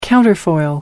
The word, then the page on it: counterfoil: American English